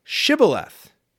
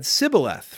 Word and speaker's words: Shibboleth: If he said no, then they said to him, "Now say Shibboleth." And he said Sibboleth, for he couldn't manage to pronounce it correctly when they seized him and killed him at the fords of the Jordan.